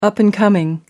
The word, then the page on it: up and coming: American English